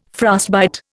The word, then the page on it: frostbite: Indian